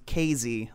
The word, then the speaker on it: Casey: And then, of course, if you're familiar with, it's spelled Casey, Illinois, but it's pronounced Casey, like the S is a Z.